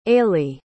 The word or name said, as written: ailidh